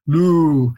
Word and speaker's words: Lu: Lu, it's a long U. Lu, it equates with the pronunciation of the Sumerian logogram Lu, which is also read Udu.